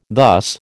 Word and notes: thus: American English

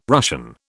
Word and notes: Russian: British English

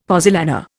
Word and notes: pozzolana: Indian

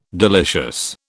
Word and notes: delicious: British English

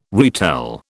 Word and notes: retell: British English

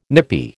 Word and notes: nippy: American English

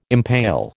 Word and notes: impale: American English

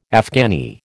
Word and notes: Afghani: American English

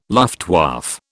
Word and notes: Luftwaffe: British English

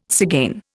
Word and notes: tzigane: Indian

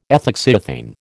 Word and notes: ethoxyethane: American English

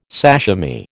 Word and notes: sashimi: American English

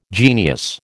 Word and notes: genius: American English

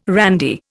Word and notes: randy: Indian

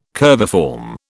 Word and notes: curviform: British English

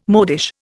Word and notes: modish: Indian

